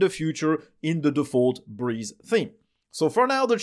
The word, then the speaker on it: Brise: B-R-E-E-Z-E, and this new theme is called Breeze, which is B-R-I-S-E. It's the same word, but spelled as it is spelled in French or German. It means the same thing.